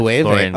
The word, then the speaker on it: Loewe: With Loewe.